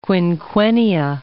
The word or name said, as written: quinquennia